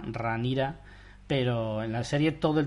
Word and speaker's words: Rhaenyra: Rain, uh, es Ranira. En la que llaman Ranira. – Ranira. – Se escribe Rhaenyra. – Rhaenyra. – Sí, como Daenerys. exacto.